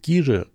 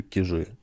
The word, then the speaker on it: Кижи: Вообще, правильно говорить Кижи, но, по-моему, это ужасно. Ну, вот я говорю Кижи.